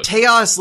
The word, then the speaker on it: Taos: I think Taos is right, but I don't mind seeing how many different ways we can pronounce it this episode. – Taos, like chaos, is not bad.